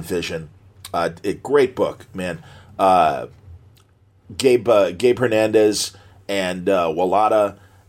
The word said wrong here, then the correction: Walta: and, am I saying it right? Walta, excuse me.